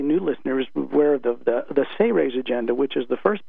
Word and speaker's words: Seres: And it has a series of 53 pages, an extensive technique section in the back of that book, and it's spelled S-E-R-E-S and pronounced Say-Raise, as if it was spelled S-A-Y hyphen R-A-Y-S.